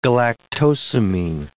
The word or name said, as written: galactosamine